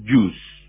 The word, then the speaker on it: Goes: Hugo van der Goes, se dice Hugo van der Jus. Pues dice, porque la G neerlandesa suena como nuestra J, excepto solo después de una N.